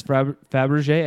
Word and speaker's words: Fabergé: So they have to steal this Fabergé egg. – How do you spell that? – I don't know. – F-A-B-R-G-E.